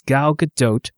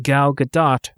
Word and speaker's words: Gadot: It's Gal Gadot rhymes with tote, not Gal Gadot rhymes with spot, nor Gal Gadot rhymes with Merlot, tarot, or escargot. She's Israeli and not French, so the T is not silent.